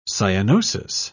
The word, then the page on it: cyanosis: British English